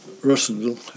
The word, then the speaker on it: Ravenstonedale: Uh, do you pronounce it Ravenstonedale or Rasendal? – Russendal. Uh, yes, the locals will abbreviate every long word in the English language.